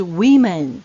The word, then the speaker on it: women: Woman，女人的复数呢也是一样，M A N 改成 M E N，但是它发音也比较不一样。女人们的发音就是 Women，女人们 Women，W O M E N，Women。所以这个单数的女人是 woman，复数的话就变成 women，要注意一下哦。